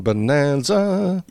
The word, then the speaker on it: Bonanza: Bonanza. – Yeah, which rhymes with Costanza, and the show's main character, the characters are the Cartwright family.